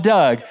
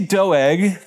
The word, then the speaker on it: Doeg: 1 Samuel 22 is all about Doug. It is actually Doeg, D-O-E-G. I didn't know how to pronounce it, and I just saw Doug.